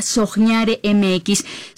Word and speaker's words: soñare: Síganos en Instagram y TikTok como @soñaremx. Se pronuncia soñare, se escribe con qué, soñaremx.